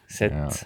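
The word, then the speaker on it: Sète: Sete linna äkki või võib see hääldus olla, et eh, Sett. – Sett. – E-d, E-d hääldata.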